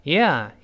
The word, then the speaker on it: yeah: Yeah，Yeah，Yeah，既不能读成 E，也不能读成呀呀。你看，还是有一个有一的啊变化的。 Yeah，Yeah，Yeah，Yeah，就变快，不能读成呀，也不是 E，这都不对啊。 Yeah, yeah，就这么个动作。那他说，Yeah，是啊，especially，尤其是这个词不好读，跟老师大声读一遍，especially，especially。